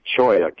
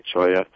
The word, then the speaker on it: cholla: And we've got for example, at the farmer's market, I like to take choya cactus. That's the one, C-H-O-L-L-A, choya.